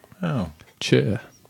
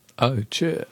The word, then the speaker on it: Ocher: O-C-H-E-R. – Ocher. Ocher. Ocher. Whatever. I don't know. How do you say it in Russian? I can't speak Russian. – Oh. – Chur. – Wow. Yeah. – Oh, chur.